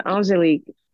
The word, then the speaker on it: Angélique: My first name is Angelique, actually. Angelique.